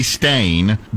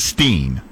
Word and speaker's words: Berenstain: According to our research, it is supposed to be stain, but more people pronounce it steen.